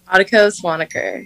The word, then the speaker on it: Adokor Swaniker: Did I say that right? – Adokor Swaniker. You're Swaniker. Really close.